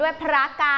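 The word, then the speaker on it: พระกรรณ: ันเด็กๆออกเสียงให้ถูกต้องด้วยนะคะพระมีรอเรือเป็นพยัญชนะควบต้องออกเสียงรัวลิ้นด้วยพระกัน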